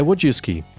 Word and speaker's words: Wojcicki: E aí a pronúncia é Wojcicki.